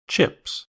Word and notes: chips: American English

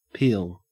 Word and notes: peel: Australian English

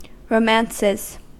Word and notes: romances: American English

